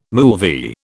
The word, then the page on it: moolvi: British English